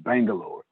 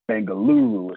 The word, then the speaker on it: Bengaluru: So the English spelling of it, pronunciation is Bangalore. Bangaluru is how you say it in India.